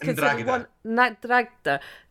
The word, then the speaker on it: Ndrangheta: Then how do you pronounce Ndrangheta? – It's Ndrangheta.